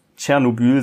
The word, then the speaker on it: Tschernobyl: Oder Tschernobyl. Es wird richtig Tschernobyl ausgesprochen. Ich habe nochmal nachgeguckt.